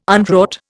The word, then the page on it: unwrought: Indian